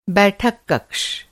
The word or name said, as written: कक